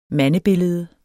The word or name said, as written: mandebillede